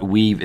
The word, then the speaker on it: WIIVV: So tell our listeners what Weave is all about. And it's spelled W-I-I-V-V.